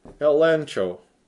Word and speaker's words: elencho: And in the Greek, it's spelled E-L-E-G-C-H-O, but since the Greeks found it impossible to pronounce G-C-H, they would pronounce it N-C-H. So you might as well spell it that way. If you spell it with a G, you wouldn't know how to pronounce it. So spell it elencho.